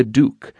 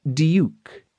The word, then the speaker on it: duke: More accurately, this book will tell you how to live like a duke, a word pronounced in England with a youp in it, or duke, and not duke, as you might say. Or, in fact, like our word few.